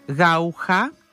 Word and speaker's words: Γαούχα: έβαλα και AI για να δω πώς προφέρεται το όνομά της και είδα ότι προφέρεται Γαούχα.